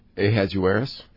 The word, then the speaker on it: Ahasuerus: Ahasuerus. – Ahasuerus. – See, the real Bible has Xerxes. – Ah, which Bible do I have? – You have the John Lukomsky approved ESV, right. No, it's Ahasuerus.